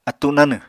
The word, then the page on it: attu nanw: Apatani